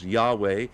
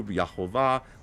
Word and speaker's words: Yahweh: Yud, He, Vav, He, the four Hebrew letters that we've talked about that represent his name, believed to be pronounced Yahweh by Latinized Hebrew, Yahovah, whichever one you prefer. I prefer Yahweh.